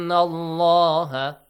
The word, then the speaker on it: Allah: But when it comes with the letter, with the word Allah or Allahumma, it has to be heavily pronounced. If there is a fatha sound before. If it is a kasra sound before, lightly.